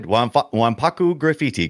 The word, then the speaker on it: Wanpaku Graffiti: Here's what the game, and if you pronounce it, I hope I get this right. Splatterhouse Wanpaku Graffiti. – Pretty good. Pretty good. Wanpaku Graffiti. Correct.